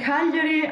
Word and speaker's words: Cagliari: Come on, it's only taking me about 15 minutes to try and listen, Matthew, by the time you are in Cagliari, I'm sure you'll pronounce correctly.